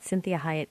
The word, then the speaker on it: Cinthia Hiett: But before we start, I want to always encourage you all to visit my Facebook at Cinthia Hiett, Inc.